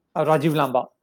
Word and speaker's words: Rajiv Lamba: Did I say that correctly? – Rajiv Lamba, yeah.